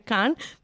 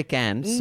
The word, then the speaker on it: Cannes: It's Netflix's film, which had created a bit of a controversy during Cannes because So Americans say Cannes. It's actually Cannes. But the French say Cannes.